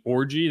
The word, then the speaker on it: Orji: The guy who stands out probably the most on the defensive side is Anfernee Orji, the guy out of Vanderbilt, who that is how you say his name.